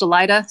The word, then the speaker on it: Alida: Also, my name is actually pronounced Alida. Sorry, I should have corrected it earlier.